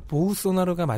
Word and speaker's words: 보우소나로: 발음이 뭘까요? 맞는 발음이. – 보우소나로가 맞을 거예요.